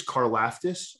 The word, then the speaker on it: Karlaftis: I think it's Karlaftis, but sure. Karlaftis sounds better. So let's just go with that.